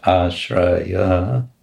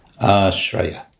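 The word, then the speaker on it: āśraya: It's not ashraya, it's ashraya. Ashraya. Ashraya, because it's short. The last two uh, uhs are short.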